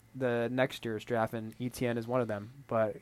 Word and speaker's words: Etienne: Etienne, yeah. – Yeah. I had no idea how to pronounce it, but I learned. It's Etienne.